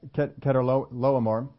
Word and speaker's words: Chedorlaomer: In the 14th year, Chedorlaomer and the kings, I pronounced that wrong before, it is pronounced like a K. Chedorlaomer